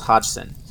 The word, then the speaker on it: Hodgson: At this time, it was occupied by a family consisting of 47-year-old divorcee Peggy Hutchinson and her four children. I'm sorry, actually her last name is pronounced Hodgson. I don't know why I got the Simpson.